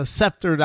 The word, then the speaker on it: sceptered: I, am adding a c. There, there, there's a silent c there. Yeah, so sceptered isle in the jewel of the North Atlantic.